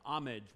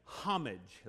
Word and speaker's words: homage: They came to worship him, homage, without the H being pronounced. I remember starting off in ministry in Scotland, and in Scotland, in Great Britain, they say homage. They use the H.